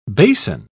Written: bason

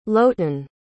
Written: Lotan

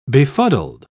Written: befuddled

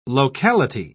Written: locality

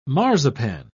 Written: marzipan

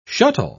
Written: shuttle

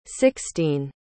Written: sixtine

